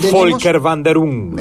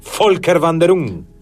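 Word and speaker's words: Völkerwanderung: Volker Wanderung. – Jamás podría haberlo pronunciado de esa forma. Maravilloso. ¿Cómo es? – Volker Wanderung.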